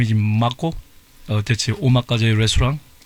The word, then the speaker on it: omakase: Omakase.